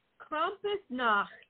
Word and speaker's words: Krampusnacht: Oh, uh, so it's Krampusnacht. Just get get in there with Nacht.